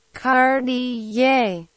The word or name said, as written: Cartier